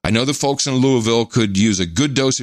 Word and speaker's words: Louisville: I currently reside in Louisville, collectively. He said, give me a pronunciation guide, Louisville, L-O-O-L-V-U-L, but I'm spending a year working for the government in the Middle East.